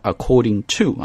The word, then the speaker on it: according to: According to 不用讲， According to 在读的时候稍微注意一下，不要把它读成 According to 啊， to 这个声音不要重，应该是很轻的，就是 t t t According to According to Research Group According to Research Group Research 重音在第二个音节，不管是名词还是动词啊，都是 Research 在第二个音节。